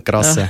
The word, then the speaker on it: Grasser: Ich heiße normalerweise Marcel Grasser. – Grasser? – Genau. – Mit Gustav? – Ja, nicht krass, sondern Grasser, ja. Mit G.